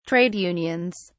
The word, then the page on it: Trade unions: British English